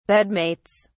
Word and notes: bedmates: British English

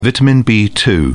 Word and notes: vitamin b2: British English

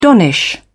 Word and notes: donnish: British English

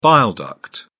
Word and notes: bile duct: British English

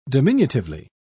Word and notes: diminutively: British English

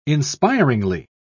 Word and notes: inspiringly: British English

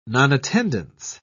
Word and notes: nonattendance: British English

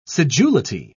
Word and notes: sedulity: British English

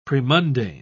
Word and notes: premundane: British English

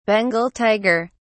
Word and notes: Bengal tiger: British English

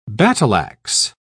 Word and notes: battle-ax: British English

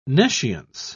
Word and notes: nescience: British English

